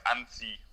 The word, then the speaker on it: Annecy: Annecy, yeah, so I call it Annecy, and then the people there pronounce it more like Annecy, like that. So depending on how local you want to sound.